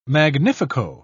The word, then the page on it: magnifico: British English